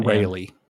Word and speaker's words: Rayleigh: Riley or how you pronounce his name? – Rayleigh.